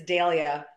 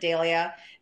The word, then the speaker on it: Dahlia: So it's Dahlia. I pronounce it Dahlia. It's probably supposed to be pronounced Dahlia or Dahlia, but I like Dahlia because my dad always pronounced it that way, and I don't like the way my mom pronounces it.